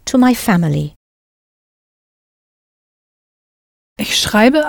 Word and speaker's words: schreibe: Richtig, ich schreibe. – Pronounce that E ending for the ich person clearly.